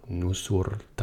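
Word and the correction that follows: Nur-Sultan: Jeg er ikke engang sikker på, at jeg udtaler det rigtigt. – Og det er jo så... Nu, nu, Nur Sultan, jo.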